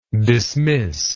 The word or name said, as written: dismissible